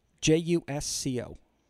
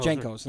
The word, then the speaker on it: Jusco: J U S C O. – Those giant pants that you used to wear. Oh, no, Jankos.